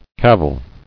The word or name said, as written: Caviled